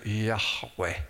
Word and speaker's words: Yahweh: You can almost hear Yahweh. Modern Jews call it Yahweh because the Wah is pronounced almost like a V now, but in the ancient Hebrew it was pronounced like a W. Wah, Wah, Yahweh.